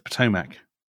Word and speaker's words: Potomac: Potomac? – It's the Potomac.